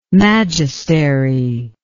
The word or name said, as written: magistery